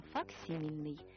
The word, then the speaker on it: факсимильный: Ударение именно такое – «факсимильный».